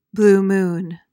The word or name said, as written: blue moon